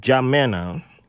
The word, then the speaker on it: N'Djamena: So, N'Djamena, the N is silent.